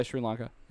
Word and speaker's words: Sri Lanka: Sri Lanka? – Yeah, Sri Lanka.